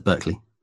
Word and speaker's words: Berkeley: In 1971, she visited Berkeley, the University of California, Berkeley? – Yeah, is that how you pronounce it, Berkeley? – That is how you pronounce it, Berkeley.